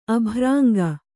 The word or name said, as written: ಅಬರಂಗ